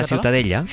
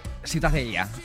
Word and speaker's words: Ciutadella: ¿cómo se pronuncia? – La Ciutadella.